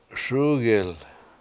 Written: SUUgil

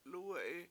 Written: lUUwe7i

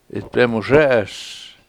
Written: 7itbemuZE7eS

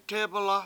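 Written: té:bɨla'

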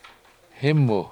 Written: hImu

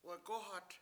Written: wagOhat

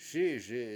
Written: SI7iSi

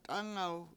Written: t$Anaw

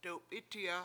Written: dewp7I7ti7a

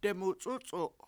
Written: demuc$U7c$u7

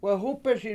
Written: wehUpesI

